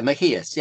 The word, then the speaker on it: Mejías: So this is an article you wrote with, I might pronounce this wrong, Ulises Mejías. – Yeah, Mejías. Yeah, he's from Mexico.